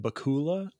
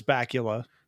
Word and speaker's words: Bakula: And so he called him Bakula forever. And I think that's a much cooler way of pronouncing it, especially Dan having a British accent. But it's Bakula.